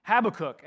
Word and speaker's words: Habakkuk: Now, I just want to say right off the bat that uh, my whole life I've always pronounced it uh, Habakkuk. – I don't know how you pronounce it, I've always pronounced it Habakkuk. And then I was watching the video I was just telling you about from Dr. Randy Smith, and he pronounces it Habakkuk.